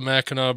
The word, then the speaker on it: Mackinac: It's actually spelled M-A-C-K-I-N-A-C, Mackinac. It makes no sense to me either, but that's what they do.